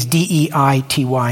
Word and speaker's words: deity: Deity is D E I T Y, in case you were wondering. If you're English and kind of snobby, you pronounce it deity we call it, I think we call it deity, but um, yeah.